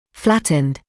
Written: flattened

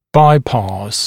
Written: bypass

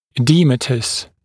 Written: edematous, oedematous

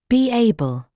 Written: be able

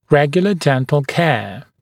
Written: regular dental care

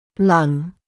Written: lung

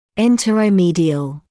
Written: interomedial

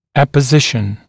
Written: apposition

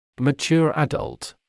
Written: mature adult

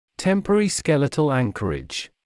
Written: temporary skeletal anchorage

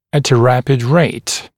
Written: at a rapid rate

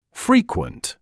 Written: frequent

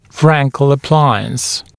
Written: Frankel appliance

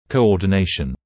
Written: coordination

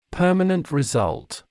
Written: permanent result

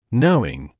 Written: knowing

